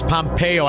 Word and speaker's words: Pompeo: You got Mike Pompeo.